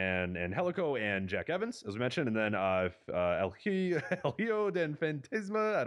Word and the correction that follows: El Hijo del Fantasma: don't know how to pronounce it, Pentagon Jr. and Tejano Jr. El Hijo del Fantasma. That was not even there it is, that El was Hijo. That was not even hard.